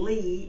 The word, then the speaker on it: Lee: M-A-R-C-I-A, a different pronunciation, and then Lee is L-E-E, and then Malden is M-A-U-L-D-I-N.